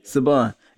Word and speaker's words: Saban: Is it Saban? S-A-B-A? Oh, Saban. Every time I see that, I just think about Power Rangers.